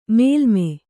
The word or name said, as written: ಮೇಲ್ಮೈ